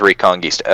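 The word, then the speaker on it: Reconguista: It's spelled, yeah, Recon G-U-I-S-T-A. – G-U-I-S-T-A? – Yeah, so Recon G-U-I-S-T-A. – Reconquista? – Okay, so it is Reconquista. Okay. – Yeah. – Just want to make sure. Okay, yeah. So the third Gundam Reconquista.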